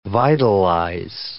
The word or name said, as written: vitalize